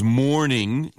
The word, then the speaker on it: mourning: M-O-U-R-N. – Yeah, the word here is mourning. Mourning. It's pronounced the same way as we say good morning.